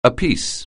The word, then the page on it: apiece: anglais